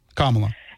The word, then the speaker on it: Kamala: Even if Kamala got arrested, Kamala. – Kamala, right. I was so good for so long. Like a whole year I said her name right.